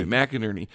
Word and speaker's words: McInerney: Well, I was going to share, I believe that Lieutenant General Thomas, I always get his name wrong. – McInerney. – I don't know why. McInerney. – Yes.